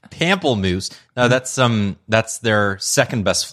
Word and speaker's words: Pamplemousse: Is that, what is it, Pamplemousse? that's the company that makes that, what is it? I know I'm saying it wrong, but it's Pamplemousse, Pamplemois. – Pamplemousse. It's the French word for grapefruit.